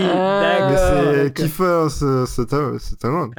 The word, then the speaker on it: Kiefer: Kisser Sutherland. – What? – C'est ça? – What? – Qu'est-ce que tu dis? Il essaie de parler. – Kiefer,